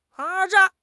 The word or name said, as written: 渣